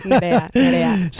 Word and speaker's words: Nerea: Nerea.